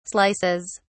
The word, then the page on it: slices: British English